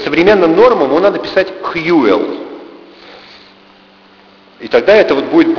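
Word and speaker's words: Whewell: Вообще, если его произносить правильно, это будет примерно так Хьюэлл. Уильям Хьюэлл. Если его произнести по-английски. Но с этим на русский язык его стали переводить, он жил в 19 веке, еще в 19 веке, и с таким, конечно, не справились. Поэтому по-русски он писался Уэвелл. Это не имеет никакого отношения к тому, как он звучит.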